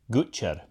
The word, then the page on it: Gutcher: Shaetlan